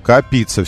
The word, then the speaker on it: копиться: Копиться все-таки, ударение на копиться.